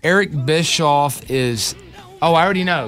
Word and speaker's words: Bischoff: I hear people say bitch off, too.